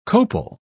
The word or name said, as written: copal